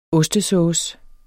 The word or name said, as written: ostesauce